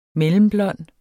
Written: MELLEMBLONDT

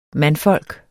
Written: mandfolk

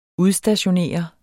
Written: udstationere

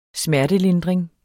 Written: smertelindring

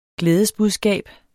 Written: glædesbudskab

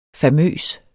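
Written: famøs

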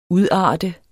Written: udarte

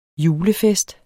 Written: julefest

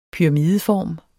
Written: pyramideform

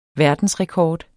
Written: verdensrekord

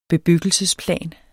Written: bebyggelsesplan

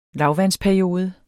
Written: lavvandsperiode

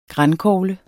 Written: grankogle

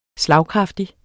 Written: slagkraftig

